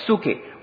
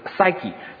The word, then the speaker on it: psyche: 那希腊语呢，在用第二个词来形容生命，就是这个 psyche，或者英文里面呢我们读成 psyche，就是从这个 psyche 我们导致这个心理学或者精神病学，就是一个它包括一个人的思想、他的感情，他的意志，就是生活生命在心里的那个领域。